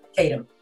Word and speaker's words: Tatum: Tatum.